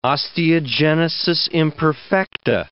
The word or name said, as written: osteogenesis imperfecta